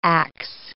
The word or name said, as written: Acts